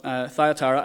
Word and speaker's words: Thyatira: But Thyatira, I think, is kind of where you should be going with that one.